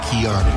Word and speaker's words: Keanu: Dit comment Keanu. – Comme Kanye.